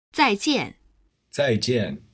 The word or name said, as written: zaijian